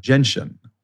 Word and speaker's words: gentian: I thought it was genetian. – Gentian. – No way. – Yep. – No way. I've been saying that wrong too. I thought it was genetian root. – Nope.